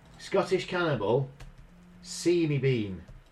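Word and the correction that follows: Sawney Bean: Right? Sawny Bean. – Sony Bean, Right.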